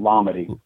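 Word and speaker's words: Lamade: Is it Lamad? Is that how that's pronounced? Lamad? – Lamady. – Oh, Lamady. That's interesting.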